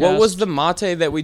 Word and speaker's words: Mate: I believe it's pronounced Mate, not Mate.